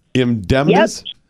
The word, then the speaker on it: Indemnis: Indemnis.